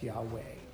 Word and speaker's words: Yahweh: It's stated in the Hebrew language, a memorial name, and it is pronounced possibly, we're not actually sure, Yahweh. It's also possibly pronounced Yahweh. It depends how you handle the Hebrew language. And this is stuff that it's the best scholars do the best they can to come out a really good answer. So consensus is it's probably pronounced Yahweh.